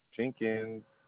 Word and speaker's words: Jenkins: A-U-N-D-R-E. – A-U-N-D-R-E. Okay. Mr. Andre. What is your last name? – Jenkins. – Please spell it. – J-E-N-K-I-N-S.